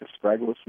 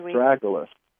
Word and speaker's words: astragalus: The Chinese take astragalus root and sometimes even ginseng roots, and they boil them in soup stock. – How do you spell that? – Astragalus. Yeah. – Oh, astragalus. Okay, gotcha. – A-S-T-R-A-G-A-L-U-S.